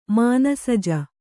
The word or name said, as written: ಮನಸಿಜ